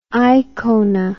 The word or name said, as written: aikona